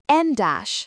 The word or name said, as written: en dash